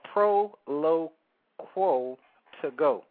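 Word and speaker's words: Proloquo2Go: But it's called Proloquo to Go. And I'm going to spell it Proloquo, the number 2, G-O. Proloquo to Go.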